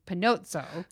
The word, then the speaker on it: Panozzo: I told her to pronounce my name in Italian with an Italian accent, which is Panozzo.